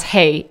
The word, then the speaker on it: hej: No, hey, no, hej, which is technically pronounced as hey in Swedish.